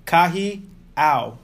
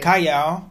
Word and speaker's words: Kahiau: K-A-H-I-A-U. Kahiao. – Bakery. – Kahiao.